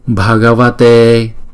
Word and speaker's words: Bhagavate: Chữ B và chữ H ghép lại chúng ta đọc là B, B, Bhagavate, Shakyamunaye.